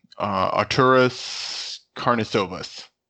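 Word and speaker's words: Artūras Karnišovas: Arturas Karnisovas.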